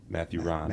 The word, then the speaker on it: Matthew Rahn: And then how do we say your name again? – Matthew Rahn. – Matthew Rahn. – Yep.